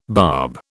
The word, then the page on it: barb: British English